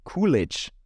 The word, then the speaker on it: Coolidge: Cool wie, ah, ich glaube, ich habe sie überhaupt falsch ausgesprochen. Sie heißt Jennifer Coolidge.